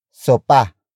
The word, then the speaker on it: sopa: The next word is sopa. Sopa. Sopa. Sopa is spelled as S-O-P-A.